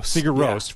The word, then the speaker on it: Sigur Rós: You know, talking about driving, that reminds me, I always pronounce, or I don't know how to pronounce the band's name, Sigur Rós from Iceland. – Sigur Rós from Iceland.